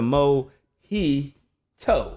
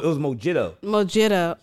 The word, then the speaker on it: mojito: I spelled it out like, was it mo he toe? Like T O E. Like that's how he pronounced it. Like, yeah, his was like mo, like, oh my God.